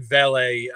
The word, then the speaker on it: Vele: And then I don't remember how to say the other kid's name, Vele or Vili. – Yeah. So, Devon Vele, yeah, he also flirted with the NFL draft, ultimately decided to come back, had a breakout season last year.